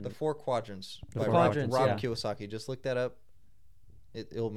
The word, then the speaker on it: Kiyosaki: Kiyosaki.